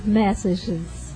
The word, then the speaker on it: Massachusetts: Como é que pronuncia, o nome dessa música? – Massachusetts. – Lá, a brasileirada lá fala Massachusetts. – Massachusetts.